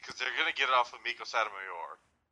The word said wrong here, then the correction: Satomura: Or Satomura, sorry.